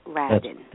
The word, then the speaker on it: Radin: sorry I mispronounced your name so it is Raiden not Radin. That's, that's right. Radin.